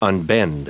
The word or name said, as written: unbend